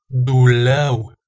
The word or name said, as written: dulau